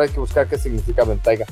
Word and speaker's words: Bentayga: Aiga, ventaiga, no se dice ventaiga, se dice ventalla. – No, sí es ventaiga, esta vez sí es ventaiga.